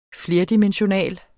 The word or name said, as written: flerdimensional